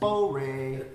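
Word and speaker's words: Bo Rei (般若): But then it's weird because modern Mandarin, they're pronounced Bo Rei, which doesn't sound anything like pranya.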